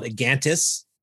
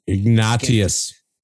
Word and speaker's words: Ignatius: So there was a fellow called Egantus. – Yeah. – Donnelly. Not the Black Donnellys. Not Donnelly Ford. – Ignatius.